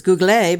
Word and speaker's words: Google: Anyway, okay, so first off, Nancy Neighbors in Paris, and her assignment while there was to check out the pronunciation of G-O-O-G-L-E, which we like to refer to as Google, because we actually think that that's how they say it in France. But guess what? Wrong. They don't say it that way. They say it like we do, Google.